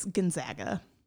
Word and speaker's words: Gonzaga: It's Gonzaga. – Okay. It's not Aga. – It's not Aga. It's Aga. – Gonzaga. I think I knew that. – Because he was like, they're the Zags, not the Zogs.